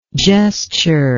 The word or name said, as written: gesturer